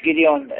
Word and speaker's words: Gideon: Is it Gideon? – Yeah, one of the judge. – Yeah, the judge Gideon.